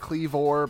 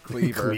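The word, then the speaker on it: Kleavor: Some do pronounce it Cleavor, but I looked it up, and it is technically just pronounced Cleaver.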